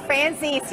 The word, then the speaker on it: Francis: All right, let's go down to Dr. Gina, who's down in West Palm Beach at the is it Frances? Am I pronouncing that right? This race, 11,587. What is it, Dr. G? – It's Francis. You're making it too fancy.